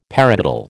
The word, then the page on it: paradiddle: American English